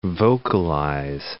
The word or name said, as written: vocalize